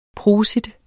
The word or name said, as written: prosit